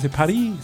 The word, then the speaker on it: Paris: That's what this is, Paris, silent S.